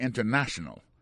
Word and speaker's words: international: Par exemple, en français, on dit international. En créole, c'est international. Mais en anglais, les différences, c'est international. Ouais, accent tombé sous na. Gende l'air, il tombe sous dernier syllabe là.